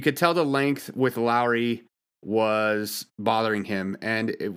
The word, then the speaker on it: Lauri: Now, Lauri Markkanen, I got to start saying his name Lowry because that's how you say it, not Lauri.